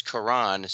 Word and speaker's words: Karan: But his name is Karan, spelled like Quran, has received millions of views, posting sweet videos of himself and his wife dancing on TikTok.